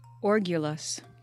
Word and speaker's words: orgulous: I still gotta get the definition, play, but how do I pronounce it? – Orgulous. – Orgulous. What did I say? Argue? – Argulous. I think you said orgulous, actually.